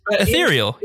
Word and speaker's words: ethereal: In the plot rundowns a couple of times, Matt has said ethereal. – Really? – Huh. – Huh. – Yeah, I always say it like cereal. It's ethereal cereal.